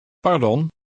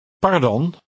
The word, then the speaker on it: pardon: And how do you say excuse me in Dutch? – Pardon.